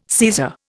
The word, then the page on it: Caesar: Indian